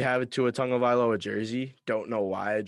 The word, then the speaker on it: Tagovailoa: I learned how to pronounce his name this past weekend. It's Tua Tagovailoa. It's like tongue. Like Tagovailoa? – No, like the body part, tongue. Tagovailoa.